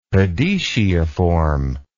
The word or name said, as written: podetiiform